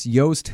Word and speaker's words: Joost: It's Joost Hilterman. It's spelled like juiced. with two O's.